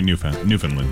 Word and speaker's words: Newfoundland: It's spelled Newfoundland, but it's Newfoundland. – You say Newfoundland.